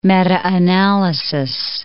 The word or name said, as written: meta-analysis